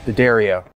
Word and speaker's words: D'Addario: And I say it right, D'Addario.